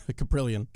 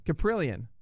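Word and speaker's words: Kaprielian: Caprillion. – Caprillion.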